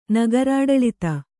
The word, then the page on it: ನಗರಾಡಳಿತ: Kannada